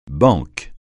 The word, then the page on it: banque: francais